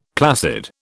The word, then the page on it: placid: British English